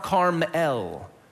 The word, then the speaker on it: Carmel: So it's not Mount Carmel, it's Mount Carmel. Because this is always about God, El. Bet, or B-E-T, or B-E-T-H, always means house.